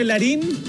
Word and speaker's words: Larin: Frente a Bosnia, el gran jugador número 9, Larin, así se pronuncia, perdón, acaba de igualar este cotejo, este partido.